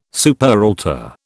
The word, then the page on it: superaltar: British English